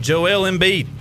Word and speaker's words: Joel Embiid: Say his name again. – Joel Embiid. – They got it that time.